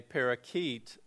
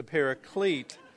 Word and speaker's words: paraclete: And again, I've cautioned other people, do not mispronounce that and call it a parakeet, but it's a paraclete.